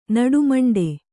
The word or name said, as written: ನಡುಮಂಡೆ